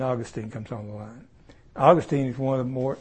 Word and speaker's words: Augustine: A man named Augustine, you spell it August, I-N-E, Augustine or Augustine. But around 400, there's a man came along, his name was Augustine. And I've heard seminary professors pronounce it either way, but I think the more refined say Augustine. Not Augustine. Okay.